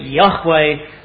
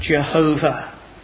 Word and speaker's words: Yahweh: Although scholars generally today believe that the original vows would have pronounced it Yahweh. And I think probably if we add up all the evidence that they're probably correct, it's more correct to pronounce it Yahweh than Jehovah.